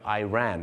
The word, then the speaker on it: Iran: ชาวอังกฤษจะเป็นอิรักกับอิหร่านแต่ชาวอเมริกาเยอะมากครับจะออกเสียงว่า Iraq กับ Iran